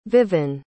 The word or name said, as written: viven